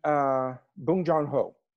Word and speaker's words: Bong Joon-ho: And Bong Joon-ho. Yeah. – Yeah, that's it. That's how you say it.